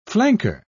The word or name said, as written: flanker